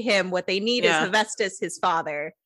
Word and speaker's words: Hephaestus: Oh, Hephaestus.